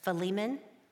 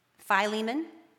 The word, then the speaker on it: Philemon: There's Philemon. I'm losing some of them. Philemon, and I just go with Phil.